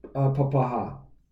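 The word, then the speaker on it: papakha: So, oh no, it's pronounced papaha.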